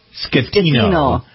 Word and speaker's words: Schettino: It's Schettino? S-C-H-E. – The C-H is pronounced like a K. – All right. – It's chicken Schettino.